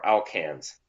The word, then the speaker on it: alkanes: separation of alkalines, or alkanes, sorry.